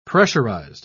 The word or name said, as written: pressurized